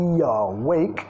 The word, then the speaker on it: awake: Stay awake. Que okay, vamos a vincular acá y la primera sílaba, awake. Awake. Primera sílaba reducida, segunda enfatizada.